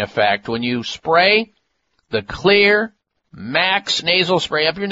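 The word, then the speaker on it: Xlear: This is a nasal spray that contains not only xylitol, and that's why Clear is spelled with an X, X-L-E-A-R. The X is for xylitol.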